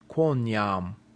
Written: quoniam

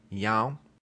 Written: iam

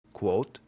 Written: quot

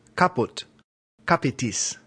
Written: caput

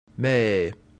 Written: me

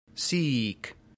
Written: sic

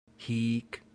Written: hic

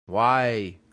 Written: vae

